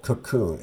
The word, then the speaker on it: Kukun: That new service is called Cocoon. It's spelled K-U-K-U-N, Home Intelligence. And here's the COO, Bill O'Walker, to fill us in on what Cocoon offers.